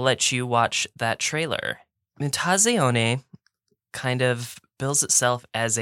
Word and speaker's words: Mutazione: And this is, it's pronounced via the Italian word for mutation. It's called Mutazione.